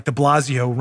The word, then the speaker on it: De Blasio: Speaking of morons like De Blasio rhymes with DeFazio.